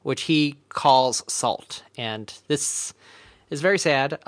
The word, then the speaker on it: NaCl: And so you're supposed to pronounce NACL as salt.